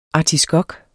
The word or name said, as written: ARTISKOKKER